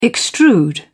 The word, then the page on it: extrude: British English